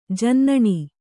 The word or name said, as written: ಜೇನುನೊಣ